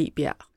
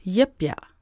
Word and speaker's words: Jebjerg: Det staves jo J-E-B-J-E-R-G, så jeg sagde Jebjerg. Men det udtales altså Jebjerg.